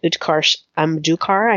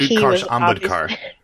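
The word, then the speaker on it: Utkarsh Ambudkar: And it also stars the beloved Utkarsh Amdukar. I hope I said that correctly. – Not even close. – But Utkarsh Amdukar. – Yeah, him.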